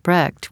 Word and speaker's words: Brecht: And we also had the issue with the pronunciation of Bertolt Brecht, which is in America Brecht, and in Germany Brecht.